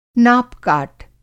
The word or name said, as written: नाप-काट